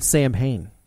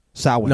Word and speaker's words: Samhain: I think you mean Samhain. – Samhain. – No, I know. – It's actually pronounced.